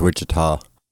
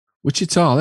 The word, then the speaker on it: Wichita: We need American viewers or listeners to say, is it Wichita or is it Wichitawww? – I think it's Wichita. – Wichita, there you go. Shaggy says it's Wichita.